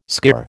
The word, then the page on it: skirr: American English